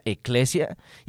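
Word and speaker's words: eclesia (ἐκκλησία): Esto, la palabra iglesia viene de una palabra griega que se pronuncia eclesia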